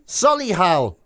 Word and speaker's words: Solihull: Solihull? I'm not sure how to say it. Solihull, I was told. – Oh, Solihull.